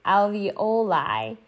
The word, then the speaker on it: alveoli: And by the way, I said it wrong in the webinar, but I relearned how to say the word. It's alveoli.